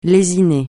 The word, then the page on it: lésiner: francais